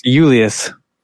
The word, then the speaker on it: Julius: So Julius was the J sound used to be pronounced with a Y sound. – Yeah. – So it's Julius.